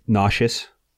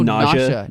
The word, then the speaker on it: nausea: Is that how you pronounce it? – Nauseous. Or nausea? – No, nausea.